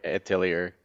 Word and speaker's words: Atelier: How do you pronounce that one? – Atelier. Or Atelier, actually.